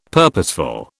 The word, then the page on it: purposeful: British English